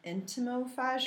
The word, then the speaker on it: entomophagy: Entomophagy? – Entomo, so like entomology.